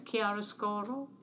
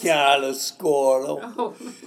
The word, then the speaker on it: Chiaroscuro: Chiaroscuro? – Yes, yes. – Am I pronouncing that correctly? – Yes. From an English point of view, yes. – Could you pronounce? – Chiaroscuro. – Oh. – See, he says it.